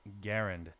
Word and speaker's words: Garand: Technically, he pronounced his name Garand.